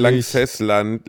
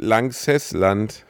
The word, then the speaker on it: Lanxessland: war in Langsessland. Lanxessland.